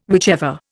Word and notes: whichever: Indian

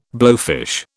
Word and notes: blowfish: British English

